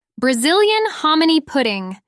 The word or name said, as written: Brazilian hominy pudding